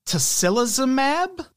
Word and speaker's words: tocilizumab: One example, tocilizumab.